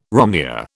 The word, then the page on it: romneya: British English